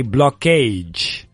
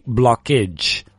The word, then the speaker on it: blockage: But when it age as a suffix, we don't say blockage. As Marta said, perfectly, blockage.